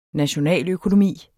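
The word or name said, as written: nationaløkonomi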